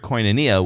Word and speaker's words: koinonia: It's a word for fellow participant that you would best is pronounced, the best way to pronounce it would be sun koinonia. It's the word koinonia with the prefix sun on it, which means to be a fellow participant in community, a co-member of the community.